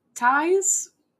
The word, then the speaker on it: Phthalates: Let me spell it. Phthalates. – Phthalates? – Think more like pterodactyl. These are phthalates.